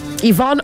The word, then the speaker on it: Yvonne: Yvonne Ochaifo. – Yeah. – Yvonne is spelled Y-V-O.